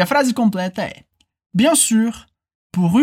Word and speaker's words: sûr: Bien sûr. Bien sûr. E atenção aqui para a pronúncia do sûr, que aqui a gente tem o som do biquinho do francês, que é uma mistura de I, com, U.